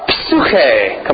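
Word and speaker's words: Psuche (ψυχή): Psuche. Come on, say it. Psuche.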